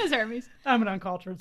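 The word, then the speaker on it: Hermès: And speaking of Lux brands, Hermes is literally Hermes, but pronounced fancy.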